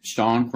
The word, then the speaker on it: Sean: So if you go onto Facebook and you just type in Sean Cronin, my name, and you type in agent, my business page will pop up. So my name is spelled S-E-A-N, last name Cronin, C-R-O-N-I-N.